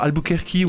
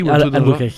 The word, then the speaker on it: Albuquerque: qu'on prononce, je crois, Albuquerque. Albuquerque.